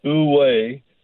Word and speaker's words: wu wei: And there's a Chinese concept, and it's basically pronounced wu wei, which translates into non-action or effortless action, can help lead more balanced and fulfilling successful lives.